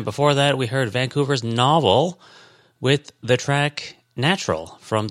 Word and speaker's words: N0V3L: It was called Novel, but it was spelt like an actual like book, like novel. But they spell their name, like the band name, even though it's pronounced Novel, N0V3L. Yeah, stylized.